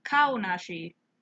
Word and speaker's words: Kaonashi: Kawanashi isn't Kawanashi. I'm pronouncing it wrong, like the dude that I listen to. Kaonashi is inside of everybody.